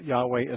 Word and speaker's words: Yahweh: Well, I think scholars have come to the conclusion that Yahweh is a more correct pronunciation.